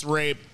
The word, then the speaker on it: rabe: I remember when I first got a job downtown, they're like, yeah, Broccoli Rob. I was like, ah, dickhead, you said it wrong. It's Rob. They're like, no, it's not. It's Rabe. What the fuck? I know Rob, and I know how to spell Rob. It's R-O-B, dickhead.